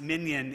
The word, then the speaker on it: minyan: But the Hebrew word minyan, pronounced minyan in English and spelled with a Y-A-N, are the 10 Jewish adults required for communal worship.